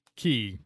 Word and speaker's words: Cay: Is there a Castaway K near there? – No, apparently that's not how you say key or spell it. – Yeah, so Castaway Key.